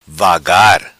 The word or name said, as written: vagar